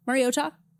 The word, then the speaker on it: Mariota: And then he was like, yeah, Mariota.